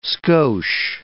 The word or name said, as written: skosh